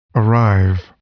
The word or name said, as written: arrive